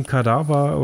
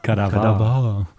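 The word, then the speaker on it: Kadavar: muss man dazu sagen, Kadaver oder Kadiva, wie man auch mal sie aussprechen mag, ist eine Kadaver.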